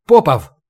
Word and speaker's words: Попов: «Попов!» — окликнул он его, делая ударение на первое «о».